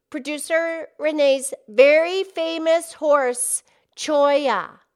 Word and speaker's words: Cholla: Yes, Choya was her one and only horse. And Choya is a name of a cactus. And the Choya cactus is spelled C-H-O-L-L-A.